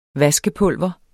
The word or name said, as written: vaskepulver